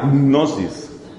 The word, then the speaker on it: gnosis: Es una palabra que hay que pronunciar como si uno estuviera tragándose un caramelo, la gnosis.